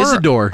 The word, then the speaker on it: Isidore: Is it Isidore? – Isidore. – Isidore. Okay, yeah.